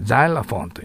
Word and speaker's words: xylophone: ザイラフォンとなります。だけど日本語ではこれシロフォンと言います。